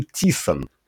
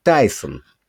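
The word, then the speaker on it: Tyson: Но кто такой Тисон? Полтора года назад ни о каком Тисоне и слуху не было. Я мог, зная английский, предположить, что на самом деле его фамилия читается как Тайсон.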